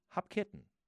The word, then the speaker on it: Hopkinton: Yes, he said he was surprised to hear that it was almost like hop and kitten, hop with the pronunciation, with the stress, I should say, on the kitten. So it would be Hopkinton.